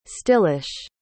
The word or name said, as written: stillish